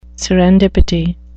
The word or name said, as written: serendipity